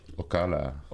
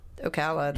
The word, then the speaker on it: Ocala: Ocala. – Ocala, that's how you pronounce it.